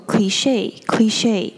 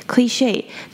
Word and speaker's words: cliché: 那这个字呢，它念作 cliche。那 cliche 这个字比较特别，因为它是源自于法语，所以它最后的三个字母 che 可以注意到它的 e 上面有小小的一撇，所以 CHE 这个字不是念 che，它是念作长音的 cliché。